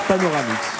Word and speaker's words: Panoramix: Ouais, il y a une petite inversion de syllabe, mais peut-être va venir à... – Panoramix ? – C'est ça, c'était ça, voilà. Panoramix.